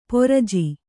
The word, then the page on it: ಪರಿಜು: pariju